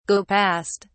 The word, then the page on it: go past: British English